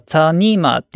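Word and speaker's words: 草泥马: で、最初可愛いから、なんかそのアルパカ置いてるのかなと思ったら、エピソード聞くと違って、そのアルパカ中国から来たやつで、中国多分ご存知かもしれないですけど、中国だとそのアルパカ、漢字で書くと草泥馬って書いて、中国語だと僕発音できないですけど、ツァニーマーっていう風に言うらしいんですよね。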